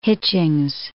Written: Hitchings

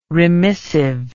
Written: remissive

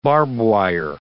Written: barbwire

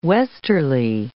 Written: westerly

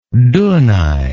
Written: dunaj

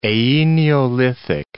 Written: Aeneolithic

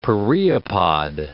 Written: pereopod

